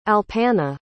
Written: alpana

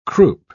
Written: croup

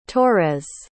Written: torres

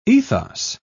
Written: ethos

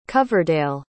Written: coverdale

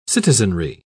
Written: citizenry

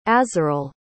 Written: azarel

